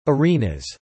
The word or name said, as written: arenas